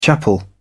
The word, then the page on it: chapel: British English